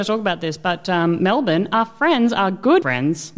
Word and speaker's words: Melbourne: Because we're Australian, we say but Melbourne. – How do you spell it, motherfucker? – Well, exactly as it's not pronounced. – Yeah. I was making a point for everybody else out there in the world. It's Melbourne. We don't spell it like that, but that's how you say it.